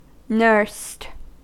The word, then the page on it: nursed: American English